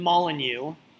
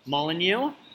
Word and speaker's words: Molineux: And we just finished, minutes ago, watching Wolves Man U in Molineux. Molineux? I think I've pronounced it much better this week, thanks to Stu helping me out with that.